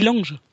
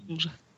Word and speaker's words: Longe: Wozu dient die Longe? – Die Longe. – L-O-N-G-E? – Genau. – Was? – Nee, Longe mit N. – Nee, mit L.